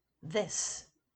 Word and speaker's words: this: This is pronounced with the short I, as in kiss, this, whereas these is pronounced with the long I, as in keys, these.